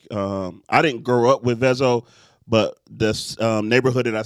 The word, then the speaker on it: Vezo: Make sure you say his name right. Vezo.